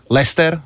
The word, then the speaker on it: Leicester: Crystal Palace, e, Leicester, nikdy neviem, ak sa ten Leicester vyslovuje. – Leicester. – Leicester, Leicester, Leicester. Odkedy takže... vyhrali, odtedy viem, ako sa to vyslovuje.